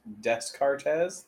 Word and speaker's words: Descartes: Pronounced Descartes.